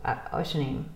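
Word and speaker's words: Oświęcim: And then as we were driving, and I knew that there are two different, actually there's three different ways of getting to Oshanim. Auschwitz, mm-hmm. Basically, just how you pronounce it in Poland.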